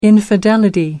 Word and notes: infidelity: American English

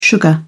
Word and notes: sugar: British English

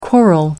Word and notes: quarrel: American English

